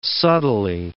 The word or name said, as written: subtly